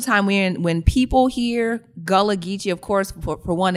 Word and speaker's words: Gullah Geechee: Um, uh, and I, you'll tell me if I'm pronouncing it right or wrong. Uh, Gula Geechee? – Gullah Geechee. – Gullah Geechee. – Yeah, so, Gullah Geechee.